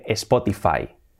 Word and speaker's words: Spotify: escuchamos siempre Spotify, Spotify. La pronunciación, pues, sería Spotify, Spotify. Cambia mucho, ¿verdad? Spotify, Spotify. Sobre todo no digáis Spotify con una E, Spotify, Spotify, y la T hacemos suave, Spotify.